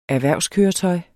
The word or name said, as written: erhvervskøretøj